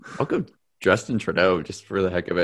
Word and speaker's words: Trudeau: Is it Kim Jong Un, Donald Trump, Emmanuel Macron, or Justin Trudeau? Is it how do you pronounce it? – Trudeau.